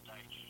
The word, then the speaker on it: Deitsch: Is it Deitsch or Deitsch? – It's Deitsch, yeah.